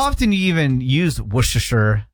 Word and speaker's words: Worcestershire: Like W-U-H-S-T-U-H-S-H-R. – Same as like, no kidding, Worcester. – Right, exactly. Yeah, Worcester is the county that I'm from in Massachusetts. – Worcestershire. – Worcester, Worcester. S-T-U-H, Worcestershire, S-H-R.